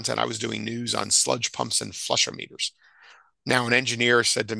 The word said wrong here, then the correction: flushometer: me, well, it's actually pronounced flushometer, but I said flushometer gets a lot more laughs.